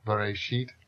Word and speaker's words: Bereshit: Bereshit bara Elohim. – Bereshit. – Bereshit. – Bara. – Bara. – Elohim. – Elohim. Good. Thank you. My accents are terrible. Everybody's heard that? Bereshit.